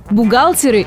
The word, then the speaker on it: бухгалтеры: Ударение в этом слове вообще неподвижное. Как и в единственном числе ударение падает на «а», так и во множественном. Так что всегда и везде будет «бухгалтер» – «бухгалтеру», о бухгалтерах – «бухгалтерам», «бухгалтеров» и «бухгалтеры».